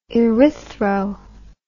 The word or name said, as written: erythro